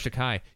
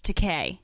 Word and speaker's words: Takei: Well, first I thought it started with a D, but it was... No, it's Takei. – Takei means expensive, so he's fine with you calling him that because...